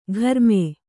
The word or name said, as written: ಘರ್ಮೆ